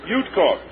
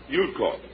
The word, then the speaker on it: Udkoff: So, this is Yutkov. Spell it for me. – U-D-K-O-F-F. – Yutkov. Yeah. – U-D-K-O-F-F.